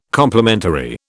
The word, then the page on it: complimentary: British English